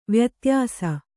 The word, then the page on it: ವ್ಯತ್ಯಾಸ: vyatyāsa